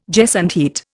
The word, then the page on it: gesundheit: Indian